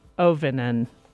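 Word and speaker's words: Auvinen: Rough Beauty is an inspirational memoir from award-winning poet Karen Ovinen. Oh, did I just say that wrong? – I think you did it right.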